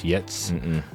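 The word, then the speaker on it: Giertz: Her name is looks like it's spelled Gertz, but it's actually pronounced Yetz.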